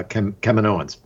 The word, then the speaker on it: Kaminoan: I think Hunter said it for the first time, Kaminoan, and you know we've seen Star yeah, Wars.